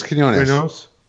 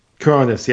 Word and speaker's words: Quinones: And one of the things I like about it, you know, it's written by Sam Humphries and drawn by, hopefully I get his name right, Joe Quintos? – I thought it was Quinones. – Quinones, yeah, thank you.